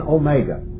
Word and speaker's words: omega: And then omega. Now, everybody knows what omega is. It's just a long O. That's all it is.